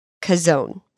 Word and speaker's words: Chazown: It is C-H-A-Z-O-W-N, Kazone.